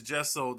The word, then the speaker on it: gesso: Gesso, yeah. – Okay. – Yeah, yeah. I'm going to have to start doing that. – The funny thing is, there's this gesso